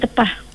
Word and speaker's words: tepah: Dia ejaan dia T-E-P-A-S ataupun T-E-P-A-H. Dia tepah. Boleh lah kedua-dua tu. – Bukan tempah ya, tepah. – Bukan tempah. Tempah itu order.